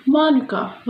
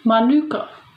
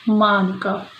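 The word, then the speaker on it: mānuka: Now we pronounce manuka with the emphasis on the ma. Unfortunately, a lot of people say it as manuka, with the stressing on the nu. No, guys, we say it as manuka. So please, say manuka, for that is the word of that fabulous honey, manuka.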